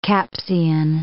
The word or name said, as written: Capsian